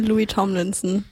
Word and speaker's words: Louis Tomlinson: Jetzt haben wir für euch Sunflowers von Louis Tom Linsner. Linson? Wie wird es ausgesprochen? Kann mir jemand helfen? – Sunflowers von Louis Tomlinson.